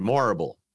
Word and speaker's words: Morrible: Michelle Yeoh has reportedly boarded the Wicked adaptation in a key role too as Madame It's Madame Horrible, but with an M instead. Morrible. Madame Morrible.